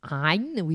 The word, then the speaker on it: Ayin: Ayin, you have to pronounce it with your throat.